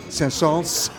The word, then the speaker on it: Saint-Saëns: En het grappige is dat hij zeer partituurgetrouw, Saint-Saëns heeft eigenlijk het meest, oh sorry, Saint-Saëns, hebben we geleerd van.